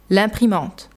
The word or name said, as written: L'imprimante